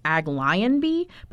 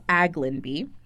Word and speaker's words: Aglionby: So now the boys go to Aglinby Academy, and I did look that one up, and that is how you pronounce it. It's spelled Aglinby. So at first I thought it was Aglionby, but it's Aglinby.